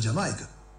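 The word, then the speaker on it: D'yer Mak'er: Del año 73, si no me equivoco, el disco de Led Zeppelin, pero ese tema se pronuncia Jamaica. – Sí, claro. – La pronunciación de ese tema es Jamaica.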